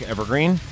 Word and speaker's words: Evergreen: I'm, of course, your illustrious host, Zach Evergreen. Zach is spelled Z Alpha Charlie Hotel. And Evergreen is just like how it sounds.